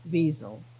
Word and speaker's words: Wiesel: And it says, Professor Elie Wiesel, I should pronounce it, Wiesel. It's like a Z, Wiesel.